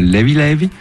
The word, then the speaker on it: Levé Levé: Alors volume 2 donc de Levé Levé, qui se prononce là-bas Levi Levi, ça veut dire léger, tranquillou un peu, il n'y a pas de stress.